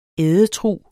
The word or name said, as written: ædetrug